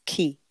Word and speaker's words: Qem: So the Q sound in ancient Egypt is pronounced key.